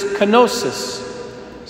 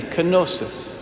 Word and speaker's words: kenosis: It's Greek, so it'll be a little hard to understand at first, but it's pretty simple, just three syllables. The word is kenosis. Starts with a K, N in the middle, and S at the end. Kenosis.